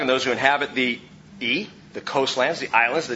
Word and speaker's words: iye: It's E. You can spell it if you're spelling it in English, I-Y-E.